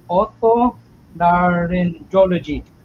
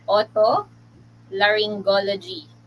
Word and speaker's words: otolaryngology: Yung otolaryngology. Did I mention it right? – Almost, sir. So it's pronounced otolaryngology.